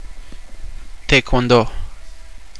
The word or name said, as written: taekwondo